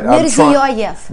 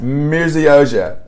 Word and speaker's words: Mirziyoyev: Uh, did I say that right? – Mirziyoyev. – Mirziyoyev. – Yeah, Mirziyoyev.